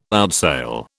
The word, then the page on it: outsail: British English